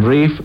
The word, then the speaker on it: Ralph: and Rafe, pronounced Ralph, you soppy git, fines.